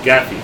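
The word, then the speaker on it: gaffi: Gaffy stick, yes. Yep. Thank you. Gaffy. Rhymes with taffy.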